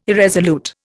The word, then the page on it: irresolute: Indian